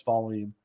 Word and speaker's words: Wallin: But Sweden, it was Vallin, which is very interesting. Because it always made me laugh when I try to hear fans try to pronounce his last name. I'm like, that's Vallin. I know it's got a W.